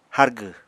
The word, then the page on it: hárgw: apatani